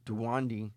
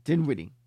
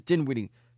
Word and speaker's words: Dinwiddie: They made it a sign-and-trade that eventually made the trade for Westbrook in the trade for Spencer Dinwiddie, probably said his name wrong, they made it into a 5-day. And Spencer Dinwiddie just came back. No, Dinwiddie. His name is Dinwiddie.